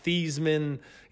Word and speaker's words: Theismann: And I had no knowledge of how the Joe Theismann, you know, rhyming with Heisman at Notre Dame came about.